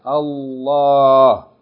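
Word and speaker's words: الله: Okay, the word Allah is written with Aleph, Lam, and then Lam Aleph. Okay, Allah. Like there's a Lam Aleph, Fatah Aleph. With Shadda on the Lam. Allah.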